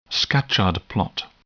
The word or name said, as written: Scatchard plot